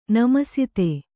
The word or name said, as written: ನಮಸ್ತೇ